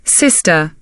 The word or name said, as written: sister